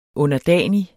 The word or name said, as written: underdanig